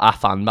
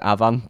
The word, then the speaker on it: Afan: Spelled Afan, but I think you pronounce it Avan, but I still can't bring myself to say Avan, because to me it's Afan. So I'll call it Afan, even though I do know if you're Welsh, it's Avan.